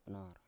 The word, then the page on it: pnor: Biak